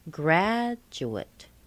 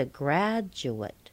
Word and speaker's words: graduate: So in three-syllable words, you'll hear da, da-dut. I'm sure you've heard graduate. He's a graduate.